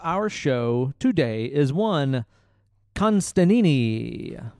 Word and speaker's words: Constantine: And Alan Moore, and Constantine, a.k.a. Constantine, which is how it's pronounced, because he's British.